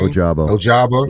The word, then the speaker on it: Ojabo: Ojabo.